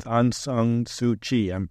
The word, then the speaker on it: Aung San Suu Kyi: And I think a lot of people are familiar with Aung San Suu Kyi. Did I pronounce that name? – Yes, it is.